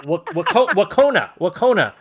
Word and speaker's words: Wahconah: Wahconah. Did I get it right? – Close enough. – Wahconah. – There you go. – There we go. Wahconah, Massachusetts Regional High School.